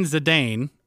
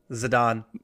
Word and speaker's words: Zidane: At the 2006 FIFA World Cup final, Zinedine Zidane, I probably butchered that name. – Zidane.